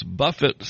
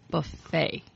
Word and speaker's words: buffet: The buffet signs? Buffet. – Didn't I say it right? – Buffet. – Buffet. – But that's Jimmy. – See? B-U-F-F-E-T. Buffet. – It's going to be a long show. – Come on. I mistranslated the mistranslation already. – Oh! – Jeez. – No, you just mispronounced it.